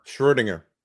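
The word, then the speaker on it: Schrödinger: And I always pronounce that Schrodinger when I read it, but I believe it's pronounced Schrodinger. I'm not sure. – Schrodinger in German.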